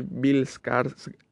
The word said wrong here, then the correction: Skarsgård: Eh, no sabía que Skarsgård significa.